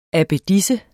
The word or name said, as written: abbedisse